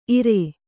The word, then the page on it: ಇರೈ: kannada